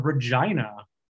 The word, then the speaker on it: Regina: A few days ago, maybe, but um, I am in a city called Regina. Um, it's they say it's the city that rhymes with fun.